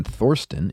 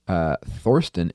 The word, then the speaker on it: Thorsten: Please note that the TH in Thorsten is no English TH. Thorsten is pronounced just like Thomas with a hard T. So it's not Thorsten, but Thorsten.